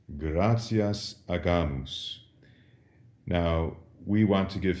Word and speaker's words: agamus: And this does something to the way we pronounce the G. When there was an I following the G in ecclesiastical Latin, that softened the G. We said agimus. But with an A, the G goes back to being a hard G, agamus.